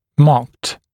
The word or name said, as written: marked